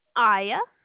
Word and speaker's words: Aiya: Aya. – How do you spell that? – A-I-Y-A.